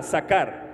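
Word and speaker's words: sakar: Sacar, con K y acento en la segunda A, sacar.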